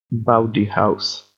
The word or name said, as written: bowdy house